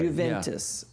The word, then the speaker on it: Juventus: No. Pronounce the J. This is the English pronunciation of an Italian word. – Juventus. – Yeah. Okay. Hard J.